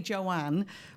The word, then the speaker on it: Joanne: If I say Joanne, which is what my name is, they say Joanne, because they can't understand that the E at the end doesn't make any noise in English.